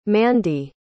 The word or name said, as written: mandi